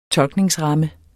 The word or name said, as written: tolkningsramme